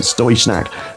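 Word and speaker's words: Støjsnak: It's spelled Stodgsnack, but Stoysnack.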